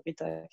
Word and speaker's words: Rhita: donc Rita c'est rita.création. Donc Rita avec un, ça s'écrit R-H-I-T-A.